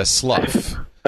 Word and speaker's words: Slough: It's not as bad as Slough.